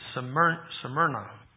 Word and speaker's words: Smyrna: Before we just all say that we would join Samaria and Philadelphia, there's a few things that we should probably notice about these two churches as well. I didn't say it right. It's Smyrna. Smyrna, it tells us, I know thy works, thy tribulation, and thy poverty.